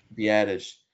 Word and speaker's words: Biadasz: Biadasz.